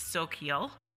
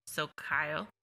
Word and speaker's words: Xochitl: His dog is spelled X O C H I T L, which is from Mexican Orient. It's Soquil. Zocayo. They say it really prettier than I do.